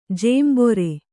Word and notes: ಜಂಬೂರಿ: jambūri

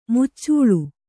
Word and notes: ಮುಚ್ಚಾಲೆ: kannada; muccāle

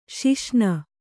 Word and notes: ಸಸಿನೆ: sasine

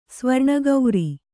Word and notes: ಸುವರ್ಣಕಾರ: suvarṇa kāra

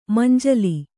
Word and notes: ಮುಂಜೋಲೆ: mumnjōle; Kannada

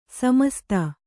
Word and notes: ಸಮಸ್ತ: samasta

